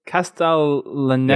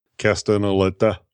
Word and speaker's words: Castellaneta: And it was a postcard that says, "Get away from it all in sunny Castellanta." I think it's how you said it. Castellaneta. – Yeah, I think that's how it's pronounced. Castellaneta.